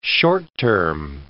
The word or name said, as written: short-term